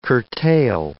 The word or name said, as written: curtail